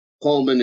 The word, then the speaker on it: Pohlmann: Pohlmann is POHLMANN, and I'll spell it all together now.